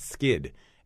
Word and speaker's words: Skydd: It's called Plant Skid, and Skid is spelled SKYDD. It's a Swedish word meaning protection.